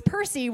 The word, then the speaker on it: Percy: And before I got married, my last name was Percy, which conveniently rhymes with mercy.